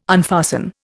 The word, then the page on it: unfasten: Indian